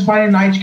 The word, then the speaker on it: Fahrenheit: No, do primeiro e do 7800 Fahrenheit, que eu sei nem como que. sei como pronuncia isso em inglês, mas. É, tá, tá, certo aí, é isso mesmo.